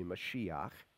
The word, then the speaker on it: Mashiach: It's the Hebrew pronunciation is really Mashiach.